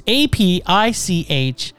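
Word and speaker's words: Apichatpong (อภิชาติพงศ์): It's APICHATPONG. What do you think that is? – Apichatpong? – Sure, that sounds pretty good.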